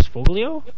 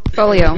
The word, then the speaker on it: Foglio: Foglio? – Folio. It's pronounced Folio.